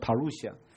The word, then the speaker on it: parousia: 可是后面那个字我想比较少人注意到或者认识，那个字读作 parousia，那是英文的读法，它直接从希腊文的音拼成的。如果在希腊文的读法是帕鲁西亚，那个希腊文你认得几个字吧？